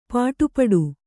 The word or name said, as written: ಪಡುಪಾಟು